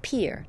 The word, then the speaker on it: Pierre: As recently as last fall, he didn't even know that the capital of South Dakota is pronounced Pierre, not Pierre.